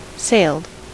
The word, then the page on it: sailed: American English